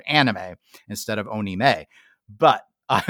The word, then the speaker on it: anime: He has used the most unique English pronunciation of anime I have ever heard in my entire life. Onime. – It's got to be some form of just maybe that's a Japanese accent. Pronunciation. No. So I always called it anime, and all the people I know who are hardcore into anime call it anime.